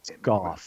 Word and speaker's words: Goff: John's been receiving information, and people have been receiving information from a woman named Susan Coff. I hope I'm pronouncing it right. – Goff. – There you go. It rhymes with cough.